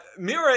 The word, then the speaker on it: Mira: Yeah, like mirror, but it's saying with some sort of accent. Mira, there you go.